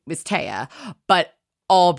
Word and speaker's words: Tea: Right, spelled T E A, but not pronounced T, obviously, pronounced Taya.